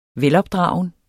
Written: velopdragen

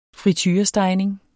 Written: friturestegning

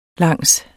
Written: langs

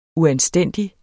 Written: uanstændig

